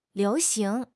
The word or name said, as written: liu2 xing2